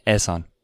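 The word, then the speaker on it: Ehsan: How did you say it? – Asan. – Asan. – Asan. – Asan. Okay. – That's how he said it.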